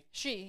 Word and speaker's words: Xi: So is it G or Z? G. – Oh, it's the first name. How it's spelled? It's spelled XI. – I know it's a spell, but I'm saying you pronounce it G. – She, yeah. – She, okay.